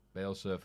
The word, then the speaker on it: Bielsa: Marcelo Bielsa for the Hall of Fame. – The Leeds manager. – Right, that's not how you pronounce his name, is it? – Bielsa, sorry.